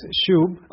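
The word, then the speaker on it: shub (שוב): And so the Hebrew word pronounced is shub.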